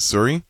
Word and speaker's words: Souris: We were way at the end of PEI, a little town called, it's spelled Souris, but I think the locals pronounce it Surrey. – Okay. – And it's like S-O-U-R-I-S.